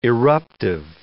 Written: eruptive

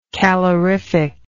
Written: calorifically

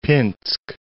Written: Pinsk

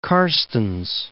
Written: Mount Carstensz

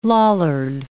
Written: Lollard

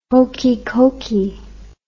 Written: hokey cokey

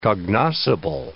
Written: cognoscible

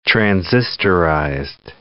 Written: transistorized

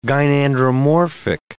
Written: gynandromorphic

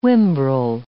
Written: whimbrel

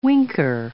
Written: winker

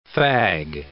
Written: fag

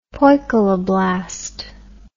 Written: poikiloblast

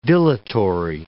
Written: dilatory

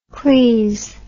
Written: cleese

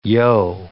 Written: yo

